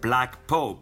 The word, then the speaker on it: Blak: Blak. Let me spell it for you. Blak is spelled without the C, B-L-A-K, Pope, P-O-P-E.